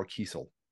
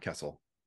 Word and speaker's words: Kiesel: And not to correct you, but it's Carl Kiesel, not Kessel. – Oh, Kiesel. – We have said it wrong forever. And then one day he finally corrected me. I think Ron corrected me. And I was like, oh, I guess it is Kiesel.